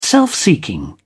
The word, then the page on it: self-seeking: British English